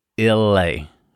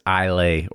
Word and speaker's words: Islay: Like, it's not, because we talked about the Chattanooga finished Scotch cast last week, and it's just, it's Islay or Illay or whatever it is, but it's a silent S in there, so apologies to all of our listeners out there.